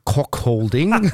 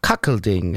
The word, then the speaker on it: Cuckolding: Also für die, die uns zuhören, schreibt man Cuck, C-U-C-K, also mit U wie Ulrich, nicht Aha. Kock, sondern Kack. Und dann noch Olding, also wie Holding ohne H. Kackelding.